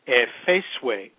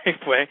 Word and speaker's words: Safeway: So growing up, I never understood what people would say when they said Safeway, because my grandmother and her sister would always pronounce it Efeseway. And so it was never Safeway. I thought the store was called Efeseway.